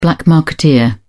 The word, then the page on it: black-marketeer: British English